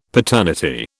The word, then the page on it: paternity: British English